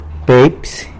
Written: Pepsy

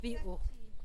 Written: biuu

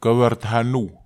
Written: kawar taha nuw

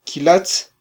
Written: kylatz

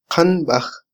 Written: qanbaj

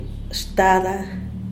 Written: shtada

